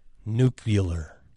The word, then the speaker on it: nuclear: Nuclear. – No. – Nuclear jewelry. – Now you're pronouncing that incorrectly. – Well, George W. Bush says nuclear.